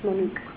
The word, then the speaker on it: MONUC: I don't know if I'm pronouncing it right. – It's MONUC.